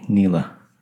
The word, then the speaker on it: Nele: Yeah, it's N-E-L-E. – N-E-L-E, Nela.